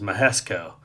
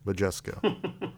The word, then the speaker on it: Majesco: Now, the former spot filled by that was Mahesco. – That is not how you pronounce the late lamented Majesco, probably.